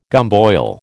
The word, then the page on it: gumboil: American English